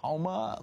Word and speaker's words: Houma: 54 minutes past the hour, and picking up your calls here, Tony in Houma, Louisiana, H-O-U-M-A. Am I saying that right, Tony? – Yeah, that's the blood red state of Louisiana.